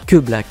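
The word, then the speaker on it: Keblack: Que Black, voilà, pour ceux qui aiment bien prononcer son nom à la française.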